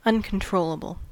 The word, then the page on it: uncontrollable: American English